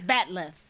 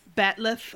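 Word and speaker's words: bat'leth: I'm positive I'm not doing the correct Klingon pronunciation. – I think you're right. It's Batleth. – Batleth, yeah.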